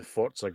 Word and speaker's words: Forza: Same with the new Forza game. No, sorry, we're mispronouncing it again. God damn it, we talked about this beforehand. Forza. The Forza game with the Forza.